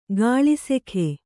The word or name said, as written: ಕೆಲಸಿಗ